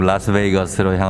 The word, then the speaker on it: 라스베이거스: 저는 지금 미국 라스베이스, 아유, 발음이 안 됩니다. 미국 라스베이거스로 향하는 비행기 안에 있습니다.